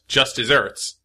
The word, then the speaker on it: just deserts: So it's pronounced just desserts, but it's spelled like just deserts.